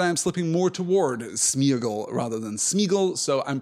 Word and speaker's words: Sméagol: the accent on the E tells us that that is supposed to be a double vowel sound. It is not supposed to be united into a single vowel sound, but Sméagol feels weird. It feels ungainly. It feels, I think, less elegant and less direct. So generally speaking, it is contracted to Sméagol.